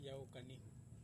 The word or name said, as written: kanihu